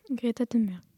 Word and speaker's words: Greta Thunberg: Greta Thunberg.